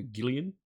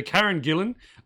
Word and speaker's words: Karen Gillan: Okay, for Sniper Wolf, I picked Karen Gillan, and it wasn't until I wrote this down I realized I've been saying her last name wrong. I've been calling her Gillian. – Yeah, that's a comic book writer. – Yeah. – Kieran Gillian. – Yep. So Karen Gillan.